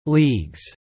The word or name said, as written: leagues